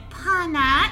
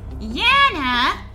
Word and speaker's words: Janna: And my name isn't Pana, it's Yana. J-A-N-N-A. It's a soft J.